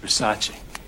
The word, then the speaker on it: Versace: It's pronounced Versace.